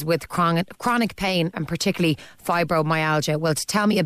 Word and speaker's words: fibromyalgia: Oh, you're very welcome, and thanks so much for taking an interest in fibromyalgia, because it's often overlooked.